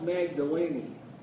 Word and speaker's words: Magdalene: It is pronounced Magdalene, mm-hmm. incidentally. She is from an area named Magdala.